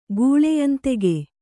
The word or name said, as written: ಗೂಳೆಯಂದೆಗೆ